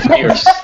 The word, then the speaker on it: Fiers: Nope, not at all. Wilco Fiers. – Fiers. He's a very fierce guy.